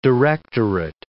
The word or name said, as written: directorate